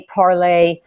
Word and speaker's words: Parler: We're talking about Parler this morning, or Parlay. How do you pronounce that? Je suis Parlay. – What is that? – I think it's Parlay. It's Parlay.